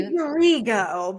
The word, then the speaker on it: Rigo: Did I say that right? I asked you. – Rigo, but that's okay. – Rigo. I put a little Wisconsin slang on it.